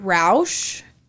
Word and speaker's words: Roush: Idaho State Police Lieutenant Robert Roush. It's spelled like Roush Racing. So that's how I'm going to say it.